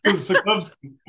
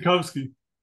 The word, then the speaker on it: Sotkovsky: Nobody ever does. Sotkovsky. – How would you pronounce the last name? Craig Sotkovsky. – Sotkovsky. So it should be fast.